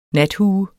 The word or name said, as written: nathue